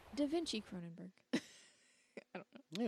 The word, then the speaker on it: Cronenberg: David Cronenberg plays a priest in this. Right? It's David Cronenberg? – Yeah. – Okay. I always feel like I get his name wrong.